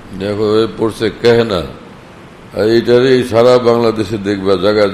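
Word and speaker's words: কেহনা (کہنا): কেহনার উচ্চারণ এটা না জানার কারণে গোফতান কাহনা কি হবে, এটা কাহনা না কেহনা,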